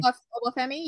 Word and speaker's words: Oba Femi: We have for the NXT North American Championship, we have Oba Femi. Did I say that right, Femi? Because Jeffrey always tried to correct me. Did I say it right, Femi? – Oba Femi, yeah. – I said it right.